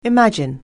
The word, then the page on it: imagine: anglais